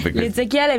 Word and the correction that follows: Ezechiele: Vedi, ma l'italiano è Ezechiele.